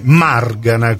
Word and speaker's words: Margana: Ecco, magari sarete felici e soddisfatti quando inizieranno anche i trapanesi a chiamare la vostra casa editrice Margana, con l'accento sulla prima A.